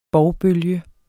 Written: bovbølge

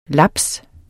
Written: laps

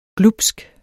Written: glubsk

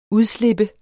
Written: udslip

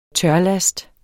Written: tørlast

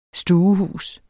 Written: stuehus

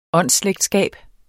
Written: åndsslægtskab

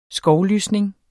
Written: skovlysning

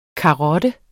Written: karotte

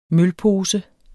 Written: mølpose

